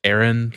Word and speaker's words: Arrand: My last name is just pronounced Aaron, like running one. Very simple, but very difficult spelling.